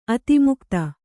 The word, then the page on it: ಆತ್ಮಕಥೆ: ātmakathe; Kannada